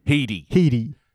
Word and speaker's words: Headey: But yeah, it is spelt like head, but it's pronounced he, not helped by the fact that, yeah, it's the same syllable sound, but it's spelt E-Y at the end, so it's Headey.